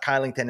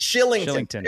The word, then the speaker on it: Kylington: It's not Kylington. It's something. – Shillington. – Else. Shillington. There you go.